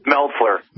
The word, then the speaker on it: Meltzer: Author Brad Metzler, how do you pronounce his name? – Meltzer.